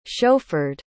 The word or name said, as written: chauffeured